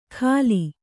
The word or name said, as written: ಘಾಲಿ